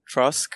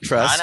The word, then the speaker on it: Trusk: All right, Trusk, not Trust. – I know. – Yeah. – Make sure you guys pronounce the K.